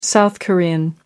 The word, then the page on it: South Korean: American English